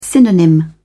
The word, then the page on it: synonym: British English